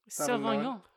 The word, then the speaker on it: Sauvignon: How do you say the second word? – Sauvignon. – Sauvignon, I believe.